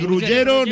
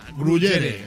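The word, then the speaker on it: gruyère: Digo, ni gruyero ni gruyera. Gruyere.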